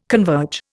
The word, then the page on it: converge: Indian